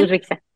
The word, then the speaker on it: Uzbekistán: Pero lograste participar representando, dime si lo digo bien, Uzbekistán. – Sí, Uzbekistán.